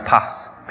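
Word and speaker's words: pass (πᾶς): It's a really simple word, which is pronounced pass, P-A-S. Really simple, three-letter word, pass.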